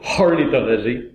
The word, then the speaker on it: ornithologie: je, sais de, quand je prononce ornithologie comme tel qu'écrit sur la pochette, je, mets l'emphase sur la H. Donc c'est ornithologie, comme le des, des, gens qui sont horny,